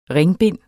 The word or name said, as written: ringbind